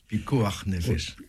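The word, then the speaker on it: pikuach nefesh: Alors il y a un principe dans le judaïsme, pikuah néphèche. Sauvegarde de la vie. – Pikuah nefesh. – Alors, je prononce évidemment très mal, mais vous vous prononcez beaucoup mieux.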